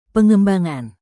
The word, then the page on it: pengembangan: indonesian